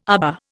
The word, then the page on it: aba: Indian